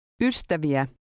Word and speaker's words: ystäviä: Ystäviä.